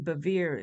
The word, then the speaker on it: Bevere: And if you haven't heard of her, her last name, Bevere, is spelled B-E-V, as in victory, E-R-E.